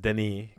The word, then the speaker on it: Denis: I believe it's pronounced Denis because she's from France.